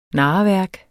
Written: narreværk

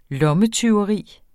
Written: lommetyveri

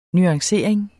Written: nuancering

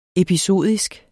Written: episodisk

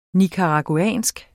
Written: nicaraguansk

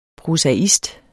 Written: PROSAISTERNES

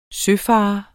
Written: søfarer

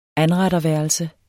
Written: anretterværelse

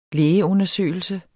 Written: lægeundersøgelse

